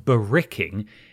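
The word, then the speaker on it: barracking: As an Australian who was two episodes behind on the show, I thought it best to clarify that baracking, pronounced bericking, is Australian slang for cheering or supporting, e.g.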